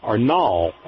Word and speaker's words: Nall: Loretta Nall, I believe it's pronounced, N-A-L-L, Nall, will be on Bob's show tomorrow night as a guest.